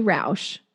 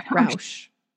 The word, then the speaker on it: Rauch: Rauch. – Like, ouch. No, we won't do that. Rauch, like couch, sort of. Yeah, sort of. That's kind of. Without the hard.